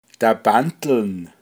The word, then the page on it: nix dagleichn toa: Pinzgauer Mundart